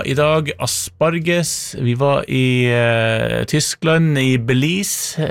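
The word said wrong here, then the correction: Beelitz: Belitz, kanskje riktig uttale.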